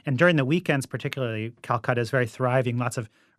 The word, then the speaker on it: Calcata: And it sounds like Calcutta in India, but it's spelled C-A-L-C-A-T-A.